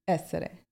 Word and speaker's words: Essere: Is it Essere Tifosi? – Essere. – Essere Tifosi. There we go. Is that good? – Yeah.